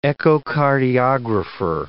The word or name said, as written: echocardiographer